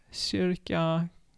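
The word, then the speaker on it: kyrka: Oder mit Y, dann wird es halt Shisa, Küssen, Shirka, Kirche.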